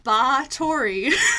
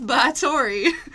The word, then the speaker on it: Báthory: is about Elizabeth Báthory, is how the pronunciation guide said it. It said to baa like a sheep. So Elizabeth Báthory, a.k.a.